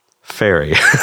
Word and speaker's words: fairy: How do you say the first one? Ferry. Say the second one. Fairy. Fairy.